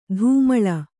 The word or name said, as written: ಧೂಮಳ